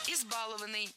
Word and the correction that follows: избалованный: Неверно. Правильно. Избалованный. Опять же, ударение на корень не ставим. Избаловать. Избалованный. И на этом пришло время прощаться.